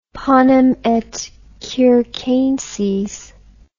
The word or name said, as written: panem et circenses